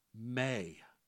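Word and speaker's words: may (μή): It starts off with a simple Greek word that is pronounced "may."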